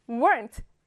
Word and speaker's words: weren't: were not의 줄임말 weren't 기억나지요? 가운데 있는 o를 삭제하고 unt 라고 발음합니다. weren't.